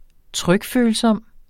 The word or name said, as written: trykfølsom